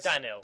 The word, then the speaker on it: Danyl: What's his name? – Daniel. – I thought it was Daniel. – Alright, spell it. D-A-N-Y-L. Daniel. – I thought it was like one of those crazy spellings, but it said the right way, like Cindy.